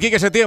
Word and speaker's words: Quique Setién: Entrenador Quique Setién, buenas noches. – Buenas noches. – Lo he dicho bien, más o menos, ¿no? – Sí, sí. – Sí, ¿no? – Bueno, lo has clavado.